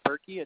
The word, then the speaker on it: Bereki: Last name is Berkey. It's spelled B-E-R-E-K-I.